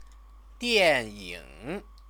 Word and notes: dianying: chinese

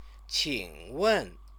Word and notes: qingwen: Chinese

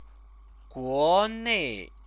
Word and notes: guonei: chinese

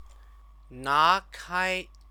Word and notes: nakai: Chinese

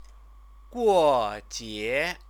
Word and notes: guojie: chinese

